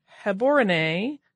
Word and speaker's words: Gaborone: So that's, I'm maybe pronouncing it incorrectly, but it's a B-A-T, not a B-O-T. Also, the capital city of Gaborone is pronounced more like Heborone with a guttural G and the accent on the final E. So I'm guessing Gaborone, and I may be still butchering that, but I am trying my hardest.